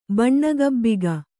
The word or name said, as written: ಬಣ್ಣಗಬ್ಬಿಗ